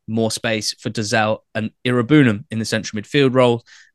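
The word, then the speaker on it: Iroegbunam: The lovable rogue nickname that doesn't really work now that I've been educated on how to actually say his name. It's Tim Irabunam.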